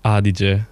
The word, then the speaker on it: Adige: There's a river Adige? Edite? – Yeah, in Italian it's Adige. – Etch. – In German, Etch.